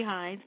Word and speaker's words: Hines: Okay, my name is Carrie Hines. My name is spelled K H E R I. Last name Hines, H-I-N-E-S.